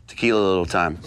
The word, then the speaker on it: Tequila Little Time: Tequila Little Time.